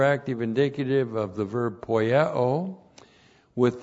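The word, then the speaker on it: poieo (ποιέω): P-O-I-E, long O, poieo. And first of all, one, poieo means to do.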